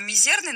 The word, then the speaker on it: мизерный: Меня немного смутило, как журналиста, то, что в слове «мизерное» ударение неправильно было поставлено. «Мизерный» надо произносить. Но я так понимаю, что рэпу многое прощается.